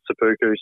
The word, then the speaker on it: seppuku: What's the is it seppuku? – Oh, you can pronounce it anyway because it's the Japanese version of the word. So yeah, I just pronounce it seppuku. So because there's two p's. So all right.